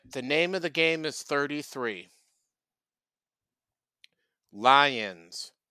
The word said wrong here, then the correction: Lyon: It's pronounced Lyon.